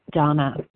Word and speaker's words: Dana: If you look me up, my name is spelled D-A-N-A, but it is pronounced Donna.